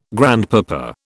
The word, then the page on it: grandpapa: British English